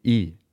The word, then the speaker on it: Billinge: Billings? Not Billings. – Is it? How's it spelled? I didn't see this written down. – Billinge. – Oh, okay. – That E throws me off.